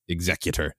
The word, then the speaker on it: Executor: I remember being yelled at, I believe, by my brother that it should be pronounced Executor.